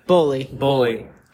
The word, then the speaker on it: Boehly: Boehly.